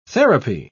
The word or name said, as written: therapy